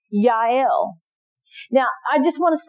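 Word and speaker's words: Jael: We're going to win, but it's going to go to Yael. And that's J-A-E-L, but I looked it up and they said it for me, and that's how you say it.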